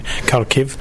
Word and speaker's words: Kharkiv: in Kharkiv, if I pronounce it right.